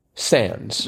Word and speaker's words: sans: And then, and the answer to that was, again, S-A-N-S. – So. – Or sans, as we pronounce it in this country.